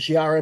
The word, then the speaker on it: Shi'ar: Oh my god, I think I got to say this wrong. The Shi'ar Empire. Did I say it wrong? – Butchered the shit out of it, mate. – Yeah. So what is it? – The Shi'ar Empire. Yes.